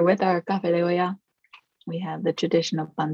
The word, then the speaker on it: olla: Café de olla. – Olla. – O-L-L-A.